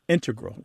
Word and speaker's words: integral: But the mathematicians and math using scientists are more likely to put, say, integral with the stress on the first syllable.